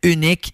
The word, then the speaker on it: Unyk: Il vient de sortir une chanson en collaboration avec un gars qui s'appelle Unique, ça s'écrit U-N-Y-K.